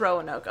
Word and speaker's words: Roanoke: Roanoke. – It is Roanoke.